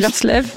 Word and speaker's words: Willerslev: Et enfin, le Danois danois, Eske Willerslev, je suis pas sûre de prononcer juste. – Willerslev. – Merci.